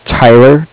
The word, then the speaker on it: Tyler: Yes, and my name is Tyler, T-Y-L-E-R, and I'm calling with Cardiff. It's spelled C-A-R-D-I-F-F, as in Frank.